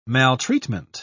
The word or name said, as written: maltreatment